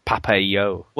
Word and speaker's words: Papo & Yo: Well, apparently the correct pronunciation in Latin American is Papa EO.